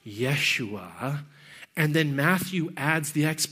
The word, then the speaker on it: Yeshua: Jesus is our anglicized sort of term for it. In the Hebrew, it would have been pronounced closer to Yeshua, a name that means God is salvation.